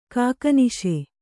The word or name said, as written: ಕಾಕನಿಶೆ